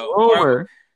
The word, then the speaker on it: Rmr: Rumor. – Or it might be a rumor that it's like only one person. – The rumor. That's how you pronounce the name, rumor.